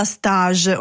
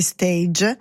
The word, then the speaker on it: stage: Ecco, quindi quando si pronuncia in quel caso si usa la pronuncia inglese. Poi arrivano gli stage invece quando inizia a lavorare, sono i periodi di tirocinio, di formazione, quindi va pronunciato alla francese.